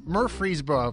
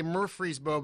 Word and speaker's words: Murfreesboro: And, of course, you have your pastoral role there in Murfreesboro, if I say it correctly, right? – Close enough. We're a suburb of Nashville, so if you say Nashville, I'll answer.